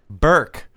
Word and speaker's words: Bourke: How do you say the last name? – Bourke. – I almost said Bjork.